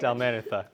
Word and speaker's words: Dalmanutha: Dalmanutha.